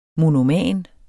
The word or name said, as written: monoman